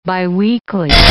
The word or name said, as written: biweekly